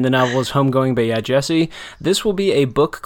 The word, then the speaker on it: Yaa Gyasi: It is by Yaa Gyasi. Did you confirm that pronunciation, by the way? I feel like you did. – I did. Okay. I watched a video where she said her own name.